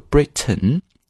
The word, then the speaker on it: Britain: Britain's royal family 就是英国的皇室，在这里注意这个 Britain，你既可以把它读成 Britain，你可以做一个鼻腔施暴。这个词呢叫 Britain 啊，你把那个 t 的时间停下来，然后直接过渡到嗯这个声音上就好了。